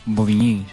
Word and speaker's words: Bobigny: Pues básicamente estas son esta gente, y paso el dato de su próxima historia, que se reúnen en Bobigny, ¿cómo se pronuncia? – Bobigny,